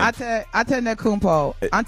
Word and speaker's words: Antetokounmpo: Antetokounmpo. So, yes, swiping right. – Antetokounmpo.